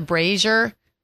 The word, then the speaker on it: brazier: Like, you know, Dairy Queen's a brazier. It's like bra, like the word bra. Z-I-E-R. What's that? – Brazier. – That's how you pronounce that.